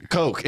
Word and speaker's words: Coke: I think it's his cock. – Thank you. Coke. It's his coke, Josh. – I'm dyslexic. – You pronounced it wrong.